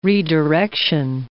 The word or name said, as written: redirection